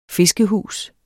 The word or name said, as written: fiskehus